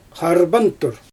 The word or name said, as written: xarbantur